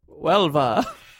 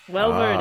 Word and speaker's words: Huelva: It's in southern Spain. You speak Spanish. – Huelva. – Huelva it is.